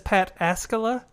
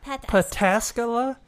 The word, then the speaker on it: Pataskala: It says Pataskala. Pataskala. Pataskala. While those in the know favor the third pronunciation, no matter how you say it, this is a unique red IPA.